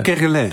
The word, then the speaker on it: Kerguelen: Nou, de nieuwe zuidoostelijke ezelspinguïn is ontdekt tussen populaties op de Kerguelen. Zeg ik dat goed? – Kerguelen, dat is volgens mij Frans. – Oh, Kerguelen. – Dus Kerguelen, laten we het zo doen.